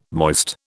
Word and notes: moist: British English

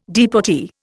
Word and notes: deportee: Indian

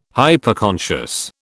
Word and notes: Hyperconscious: British English

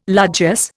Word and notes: largesse: Indian

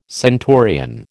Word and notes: centurion: American English

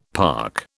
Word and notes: park: British English

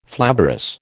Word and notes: flavorous: American English

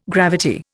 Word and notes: gravity: Indian

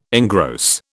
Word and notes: engross: British English

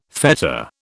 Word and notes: fetta: British English

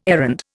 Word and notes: errand: Indian

